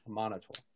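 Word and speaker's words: Amaunator: So you know that the House of the Morning Lord worships the god Amenator. Let me make sure I got the pronunciation. – Illuminator. – Amenator. – Amenator. – Amenator. – Yeah.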